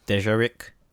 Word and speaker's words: Dejarik: It's Dejarik. D-E-J-A-R-I-K.